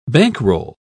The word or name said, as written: bankroll